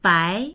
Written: bai2

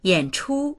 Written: yan3chu1

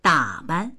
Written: da3ban

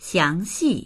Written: xiang2xi4

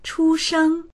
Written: chu1sheng1

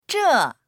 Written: zhe4

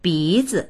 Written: bi2zi

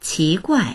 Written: qi2guai4

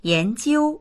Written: yan2jiu1

